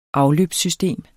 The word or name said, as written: afløbssystem